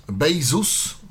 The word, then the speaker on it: Baseus: Mianowicie dzisiaj powiemy o klimatyzatorze, a bardziej ja bym to nazwał klimatorze przenośnym, małym, Bezos, pisze się Baseus, Desktop Time Air Cooler.